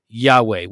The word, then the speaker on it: Yahweh: Yahweh, which is how we pronounce it now.